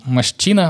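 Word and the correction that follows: Moschino: Масчина, Маскина. – Маскина, еще, ты что?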